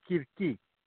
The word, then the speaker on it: Circe: Yeah, Circe is how it's pronounced in English, I guess. In Greek, it's Kirki.